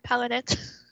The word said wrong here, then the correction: Palo Santo: Palo Santo. Yeah, holy, I would get confused.